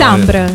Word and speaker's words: Samber: Che bravo, Samber, così si pronuncia. – Samber, sì. – Samber. – Samber. – Ah, proprio così, sì? Sì, sì, sì, è come lo leggiamo.